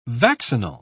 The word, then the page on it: vaccinal: British English